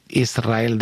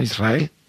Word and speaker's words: Israel: Israel, ¿no? – Y la S con la R es difícil de pronunciar. La gente dice Israel, que suene la S.